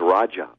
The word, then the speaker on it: Raja: Okay, Satyen, and it's spelled S-A-T-Y-E-N. And my last name is Raja, R-A-J-A.